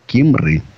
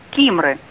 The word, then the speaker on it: Кимры: А у нас город Кимры. Здравствуйте. – Здравствуйте. Ну, немножко неправильно вы сказали. Кимры. – Кимры. Виноват.